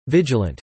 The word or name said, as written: vigilant